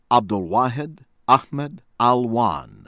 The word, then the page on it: Abdelwahid Ahmed Alwan: from Yemen